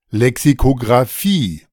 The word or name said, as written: Lexikografie